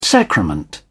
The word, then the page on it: sacrament: British English